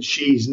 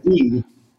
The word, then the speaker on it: Xi: I generally agreed with all of his points, except he mispronounces President Xi's name. Since I spent 5 years learning Mandarin, I think I have to correct him. Not President Xi, it's President Xi, just like S-H-E-E.